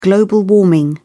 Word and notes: global warming: British English

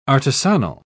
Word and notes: artisanal: British English

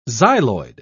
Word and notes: xyloid: British English

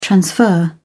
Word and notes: transfer: British English

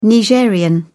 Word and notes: Nigerien: British English

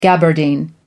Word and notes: gabardine: American English

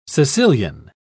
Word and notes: caecilian: British English

